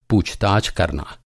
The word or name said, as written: पूछताछ